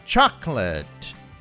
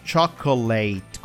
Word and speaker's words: chocolate: Comigo, You'll have to try their hot chocolate. Então, lembrando que não é chocolate, como muita gente fala, mas chocolate. Chocolate.